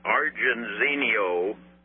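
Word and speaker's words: Argenziano: Argenzino is the way I think it is pronounced. It's A-R-G as in Gene, Genie, E-N-Z-I-A-N-O. That's A-R-G-E-N-Z-I-A-N-O.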